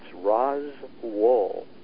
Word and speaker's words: Roswell: It's Roswell, like Ros, and then like the stuff you get off a sheep. That is the authentic way to pronounce it,